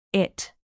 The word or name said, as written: it